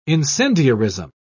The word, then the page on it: incendiarism: British English